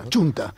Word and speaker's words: Chunta: Me llama la atención la recuperación, efectivamente, ya lo habéis mencionado, de la Junta Aragonesista, de la Xunta Aragonesista, que claramente... – Chunta. Bueno, hicieron un vídeo electoral de toda la gente que pronunciaba mal Chunta.